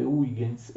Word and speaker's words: Huygens: si scrive Huygens e si legge Huygens, è la teoria corpuscolare della luce che eh, verrà ovviamente espressa al massimo livello da Newton.